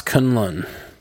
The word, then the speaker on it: Kunlun: Pronounce Kunlun.